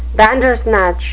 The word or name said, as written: bandersnatch